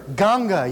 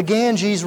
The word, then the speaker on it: Ganga: And so the Hindu sadhu, in the place where we served, a town called Varanasi, within India it's called Banaras, there along the river Ganga, you may have not heard that term before, we mispronounce and we use the term the Ganges River, the Mother Ganges River.